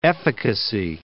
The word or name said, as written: efficacy